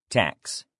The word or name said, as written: tax